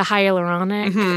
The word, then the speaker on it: hyaluronic: For the hyaluronic, I use am I saying it right? – Yeah.